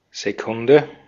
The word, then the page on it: Sekunde: [zeˈkʊndə]